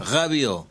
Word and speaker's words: Rabiot: Rabiot. – La T no se pronuncia.